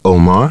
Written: Omar

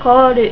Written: kooru

